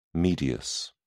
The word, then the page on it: medius: British English